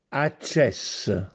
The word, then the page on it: Accèsse: Castellanetano